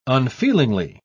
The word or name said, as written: unfeelingly